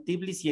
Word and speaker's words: Tbilisi: A ver, Armenia, capital Ereván, de Georgia, capital Tbilisi, en español se la pronuncia así.